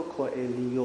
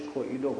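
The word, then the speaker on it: Coelho: سومین جریان معنوی آمریکایی مکتب معنوی پائولو کوئلو است. البته تلفظ اصلی و درست اسمش پائولو کوئلیو است، ولی خب به اشتباه رایج کوئلو گفته میشه.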